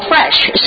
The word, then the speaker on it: Fraiche: from the restaurant called Fresh. So if you look it up, it's in L.A., and it's spelled F-R-A-I-C-H-E, and so you need to look at it like that.